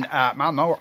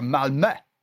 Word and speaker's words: Malmö: Well, as you know, he's in Malmö or Malmö. It's like if James Brown was asked to pronounce Malmö.